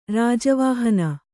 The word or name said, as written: ರಾಜವಾಹನ